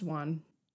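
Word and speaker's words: Wone: And it's pronounced Juan.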